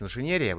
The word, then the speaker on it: инженерия: Да, дорогие друзья, и я, естественно, приношу извинения, потому что я всегда ставлю ударение не в тех местах. Конечно, правильнее говорить инженерия, вы меня абсолютно правильно здесь поправили.